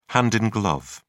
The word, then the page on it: hand in glove: British English